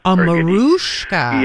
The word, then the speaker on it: Mariska: I mean, she said, by the way, she likes her name, even though it's spelt with an I, she refers to herself as Mariska. – Mariska.